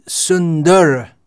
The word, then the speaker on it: Sundara: This S-U-N-D-A-R-A. And this name is S-U-N-D-A. In proper Sanskrit pronunciation, we'll find that R is clearly enunciated. Often in English, it's not. If we have it at the end of a name, or if we have the consonant R at the end of a word, it's often silent or almost silent, just like, for instance, Roger, the name Roger. So Sundar, we may say Sundar in English pronunciation, but that's a different name.